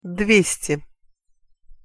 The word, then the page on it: dvesti: Russian